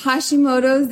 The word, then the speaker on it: Hashimoto's: Yes, Hashimoto's is a thyroid disease.